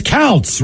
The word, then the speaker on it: Kautz: His last name is Kautz, rhymes with Scouts, from his wife, Chrissy.